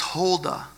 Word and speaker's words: Huldah: Verse 14, pronounced Huldah, the prophetess.